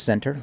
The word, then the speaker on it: Senter: Last name is Center, right? – Spell it for me. – S-E-N-T-E-R.